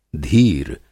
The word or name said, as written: धीर